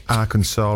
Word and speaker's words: Arkansas: Yeah, anyway, it is prohibited to pronounce Arkansas wrong because it's spelled Arkansas. And I didn't realise this till I read this full fact. The final S is silent because the name is a French spelling of a native word for the Kaw-Paw people.